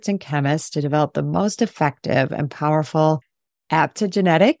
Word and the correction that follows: adaptogenic: Did I say that right? – Adaptogenic. – Yeah. Adaptogenic blend.